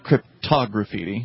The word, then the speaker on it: cryptograffiti: I mean, it looks like crypto, but you were pronouncing it cryptograffiti. – Yeah, okay. Think of it cryptography and then graffiti together.